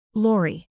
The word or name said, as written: lory